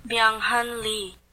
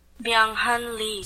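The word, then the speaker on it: Byung-hun Lee: Byung Hun Lee. – Is that how you say it? Say it again. – Byung Hun Lee. – So Lee, that's what we're saying.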